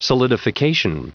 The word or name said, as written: solidification